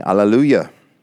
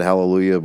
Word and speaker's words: Alleluia: In verse number 1, and after these things, I heard a great voice of much people in heaven saying, Alleluia, which is hallelujah. Like we pronounce it hallelujah, but it's alleluia, and it is a Jewish term, salvation and glory and honor and power unto the Lord our God.